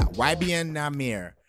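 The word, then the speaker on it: YBN Nahmir: Did I say it right? Yeah, YBN Namir.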